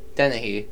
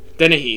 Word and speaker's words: Dennehy: Denny? – Denny? – Dennehy. – Dennehy, thank you.